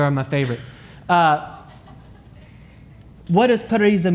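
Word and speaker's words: parisa (παρρησία): The word for boldness or courage is transliterated in Greek as parisa. Parisa. I can't even say it right. Parisa. Let's say it together on the count of three. One, two, three. Parisa.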